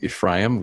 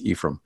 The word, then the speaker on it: Ephraim: Ephraim Radner is a professor of historical theology, and I'm going to just say at the beginning, I've been pronouncing his name Ephraim, which is the Hebrew pronunciation, but I understand the family pronunciation is Ephraim.